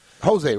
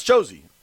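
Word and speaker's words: Jozy: Jose, right? – No, it's Josie. I'm almost positive it's pronounced. It's J-O-Z-Y.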